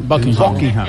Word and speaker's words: Buckingham: Buckingham. – Buckingham.